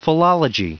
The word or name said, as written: philology